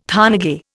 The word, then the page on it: thanage: Indian